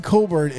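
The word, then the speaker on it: Cockburn: And also in the South region, you had number 4, Fighting Illini with Kofi Coburn. And if you want a fun thing to do on a Sunday afternoon, go ahead and Google his name because it doesn't sound the way it's spelled.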